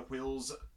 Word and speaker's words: Whills: Whills. Yes, I'm pronouncing the W-H in Whills.